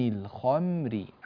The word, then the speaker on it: الخمر: ينبغي هنا أن يراعى ترقيق الميم عند النطق، لا نقول يسألونك عن الخمر خم، بعض الناس ينتقل من الخاء المفخمة إلى الميم المرققة فلا يرققها بل يفخم هذه الميم، وهذا خطأ لا ينبغي الوقوع فيه، لا نقول الخم الخم بل الخم خم بعد التفخيم، نعود بالشفتين إلى الخلف لنطق حرف الميم، عن الخمر عن الخمر هكذا.